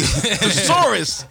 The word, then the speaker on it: thesaurus: You need a thesaurus, my nigga. You thesaurus. You put the emphasis on the wrong syllable.